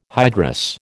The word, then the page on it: hydrous: American English